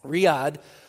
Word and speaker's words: Riyadh: Riyadh, kind of like the city, pronounced the same way as the city in Saudi Arabia.